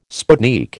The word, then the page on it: sputnik: American English